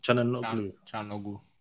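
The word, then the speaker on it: Çalhanoğlu: Shanahaglu, how do you say his name? – Chanoglu.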